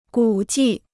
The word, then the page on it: 古迹: gǔ jì